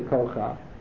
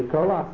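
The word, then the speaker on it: Koach (כח): When you have a Ches with a Patach at the end of the word, so you don't say Kocha, you say Koach.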